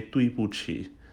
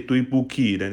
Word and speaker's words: 对不起: Tui, D sounds like T, B sounds like P, Q sounds like CH. So you say 对不起. Because if you do not pronounce that way, if you pronounce like as you what you see, then you say 对不起, then it's gonna be a very bad word already. So 对不起, remember Q sounds like CH, alright?